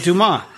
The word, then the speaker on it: Dumas: Yeah, that's what I said, Dumas. You thought I said Defoe. Listen to I'm enunciating my syllables. Dumas. Okay. It's spelled like dumbass with no B.